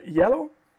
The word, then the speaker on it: Iello: Ähm, aber vielleicht mal ein bisschen mehr weg davon, gehen wir erstmal zu einem, auch noch der größeren Spiele, herausgebracht von äh, Yellow. Hier muss man aufpassen, es wird so ausgesprochen, wird aber I-E-L-L-O ähm, geschrieben.